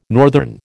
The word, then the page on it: northern: American English